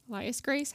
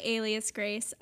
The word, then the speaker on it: Alias Grace: Elias Grace? How do you say it? – I would say Alias Grace.